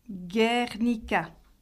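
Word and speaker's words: Gernika: Le G se prononce toujours dur, comme gué en français. Ongui, Guernica.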